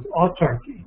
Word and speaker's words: autarky: was autarky. That's spelled A-U-T-A-R-K-Y, autarky, where,